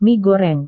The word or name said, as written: mie goreng